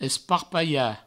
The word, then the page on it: esparpalhar: provençal; esparpaia